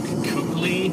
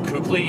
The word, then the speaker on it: Kuechly: I think Luke Kuechly, who has been in trailers up to this point, will probably be there. – Is that a real person? – Luke Kuechly, yes.